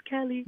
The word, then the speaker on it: Kehli: So it's Kehli. – Amazing. – So people always call me like Kaylee, and I'm like, it's Kelly.